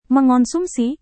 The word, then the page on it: mengonsumsi: Indonesian